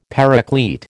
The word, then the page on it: Paraclete: American English